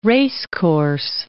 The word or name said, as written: racecourse